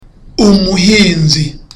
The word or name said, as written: umuhinzi